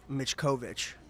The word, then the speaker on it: Mickovic: It's Mitch Mickovic. – Mitch Mickovic. – Croatia. – That's how you pronounce it.